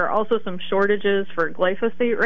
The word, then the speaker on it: glyphosate: We know about Roundup, the glyphosate.